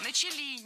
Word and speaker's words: начали: Никто особо на эти вещи не обратил внимания, хотя с того момента начали раскрываться довольно там серьезные процессы. – Начали неправильно. Должно быть «начали». Ударение в этом слове падает на первый слог.